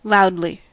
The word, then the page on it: loudly: American English